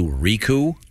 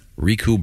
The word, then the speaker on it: Ricou: So once again, his name is, I don't know how you pronounce this, R-I-C-O-U, Riku? – Yeah. – Riku Browning.